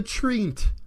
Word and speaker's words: treant: Or, if you want to fiddle with the pronunciation, the treant. – The treant? – Yeah, it's like T, but with an R, so treant.